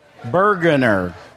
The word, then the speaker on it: Brueggener: Paul, how do you pronounce it? Brueggener. Oh, I put the R in the wrong place.